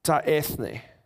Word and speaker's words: ta ethne: The word Gentile in the Greek is the word ta ethne, the nations. Okay, so that Greek term there you pronounce ta ethne.